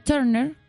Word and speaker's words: Turner: Y en, las pinturas de Turner, porque así se pronuncia, tú le puedes decir Turner si querés.